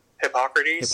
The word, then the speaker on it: Hippocrates: Hippocrates. – Hippocrates, thank you.